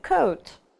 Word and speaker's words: coat: Сочетание OA у нас читается как O. Да, помню, что редуцированный звук, то есть безударный O. Ну, например, coat. Coat.